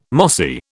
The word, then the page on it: mossy: British English